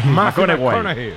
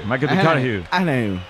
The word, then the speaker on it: McConaughey: McConaughey. McConaughey.